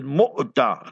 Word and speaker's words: Mu'tah: So those of you going to Jordan, you must and should visit Mu'tah. Remember that, that is the proper pronunciation.